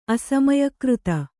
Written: ಅಸಮಯಕೃತ

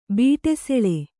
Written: ಬೀಟೆಸೆಳೆ

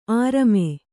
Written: ಅರಮೆ

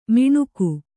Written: ಮಿಣುಕು

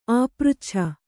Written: ಆಪೃಚ್ಛನ

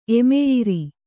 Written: ಎಮೆಯಿರಿ

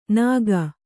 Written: ನಾಗ

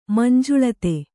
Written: ಮಂಜುಳತೆ

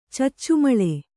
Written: ಚಚ್ಚುಮಳೆ

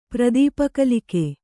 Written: ಪ್ರದೀಪಕಲಿಕೆ

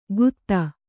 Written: ಖುದಾ

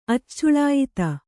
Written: ಅಚ್ಚುಳಾಯಿತ

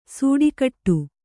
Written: ಸಿಟ್ಟುಗುಟ್ಟು

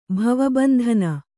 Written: ಭವಬಂಧನ

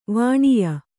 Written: ವಾನೇಯ

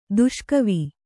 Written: ದುಷ್ಕವಿ

